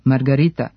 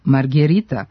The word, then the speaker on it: margherita: Italijanski specijalitet, pizza margarita, zapravo se piše i izgovara sa e kao margherita, što potvrđuje i posljednji pravopis.